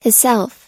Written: hisself

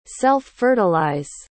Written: self-fertilize

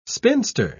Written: spinster

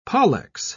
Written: pollex

